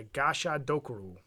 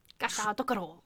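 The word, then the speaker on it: Gashadokuro: So the specific one I'm covering today is the Gasha Dokuro. And that... – Gasha Dokuro.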